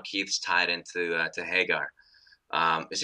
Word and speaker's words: Haggar: one thing that was said to me before was that it was spelled H-A-G-G-A-R. – Yeah. – And that should be pronounced Hagar instead of Hagar. – Like Yagar. – Yeah. So okay. I sometimes get caught saying Hagar and I don't really mean to, but it's Hagar.